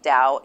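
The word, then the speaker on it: Tao: I think it's it's spelled with a T, but it's pronounced with Dow.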